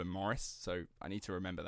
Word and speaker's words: Maurice: He is the eldest of two children to Maurice and Morris, actually, I should say. It's Morris, but it's spelt like it's Maurice, but the Australians say...